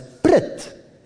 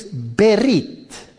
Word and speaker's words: Berit: The Hebrew word for covenant would be pronounced in its anglicized form as Brit. Now the Hebrew is Berit. So he says, let's call it Brit.